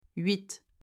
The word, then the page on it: 8: French